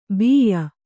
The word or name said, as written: ಬಿಯ್ಯ